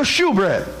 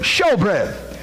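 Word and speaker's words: shewbread: This bread that they're talking about that is on the table, Sister Mary, it's called the show bread or the shoe bread. We pronounce it show bread.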